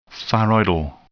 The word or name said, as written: thyroidal